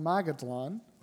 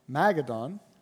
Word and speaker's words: Magadan: Magadan. I said that wrong. Magadan.